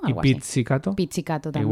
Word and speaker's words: pizzicato: pizzicato, como pizza,